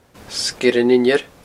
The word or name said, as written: Sgeir an Inbhire